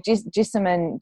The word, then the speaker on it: Jessamine: But like Jessamine, is that how you pronounce her name? Jessamine Stanley? – That's how I say it.